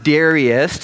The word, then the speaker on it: Darius: It pleased Darius, or it can also be pronounced Darius, to set over the kingdom satraps, to be throughout the whole kingdom.